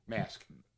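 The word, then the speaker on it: Masque: The Masquerade of the Red Death. – Mask. – Mask, sorry. – Oh, yeah. – Yeah, it's spelled differently. It's The Q-U-E? – Yes.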